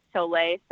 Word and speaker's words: Sole: You know, I was saying Sole Volante tube until our Italian jockey Luca made it clear that it was Sole. So I'm going to go with Sole.